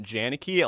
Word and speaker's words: Janicki: It's not that hard to say, but a lot of people are like Janicki, Janicki. It's like, apparently Polish names are hard to pronounce.